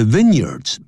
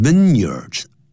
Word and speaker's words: vineyards: The vineyards, 不要念成 vineyards, 记住它合在一起要念成 vineyards, vineyard, 或者就念成 year, 连在一起 vineyard, the vineyards of the Cape, 这个开普省的这些葡萄园啊 are thought to be the best, 那么是被认为是最棒的啊。